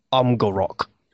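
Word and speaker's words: Amgarrak: Really, the Golems of Amgarak was absolute shit, and apparently it's pronounced Amgarok. – You've also got Morrigan's... – Despite all the A's in it.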